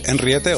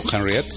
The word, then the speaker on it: Henriette: El grupo, repetimos, es Henriette o Henriette, si lo queréis pronunciar más en inglés, y esto suena así de bien y así de potente.